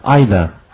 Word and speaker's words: either: Either, either. 好，这个词两种读音，再来一遍。